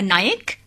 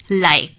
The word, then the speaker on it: নায়েক: পাইক বা নায়েক শব্দটি স্থানীয় উচ্চারণে হয়ে যায় লায়েক, যে পরিচয়েই এদের অভিহিত করা হোক না কেন।